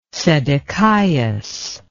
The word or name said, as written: Sedecias